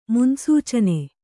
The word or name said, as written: ಮುನ್ಸೂಚನೆ